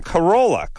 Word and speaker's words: Corolla: Plus, so it really, I say my name Corolla. I never say Cor.